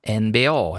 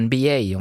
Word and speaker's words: NBA: Och basket på allra högsta nivå pågår just nu i USA, det vill säga finalen i NBA, eller NBA om vi ska uttala det på amerikanskt vis.